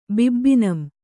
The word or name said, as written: ಬಿಬ್ಬಿನಂ